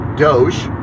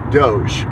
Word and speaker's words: Doge: This one is called Doge. We have researched very strong to find out it's not pronounced dog or doggy, but indeed Doge.